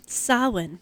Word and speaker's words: Samhain: And I, excuse me if I'm saying Samhain. – That wrong. – Samhain. – Samhain? Mm-hmm. So the M is silent. – Correct.